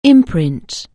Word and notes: imprint: anglais

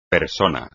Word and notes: persona: espagnol

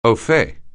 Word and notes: au fait: anglais

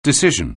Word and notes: decision: anglais

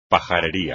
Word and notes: pajarería: espagnol

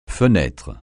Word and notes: fenêtre: francais

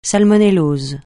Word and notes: salmonellose: francais

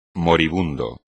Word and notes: moribundo: espagnol

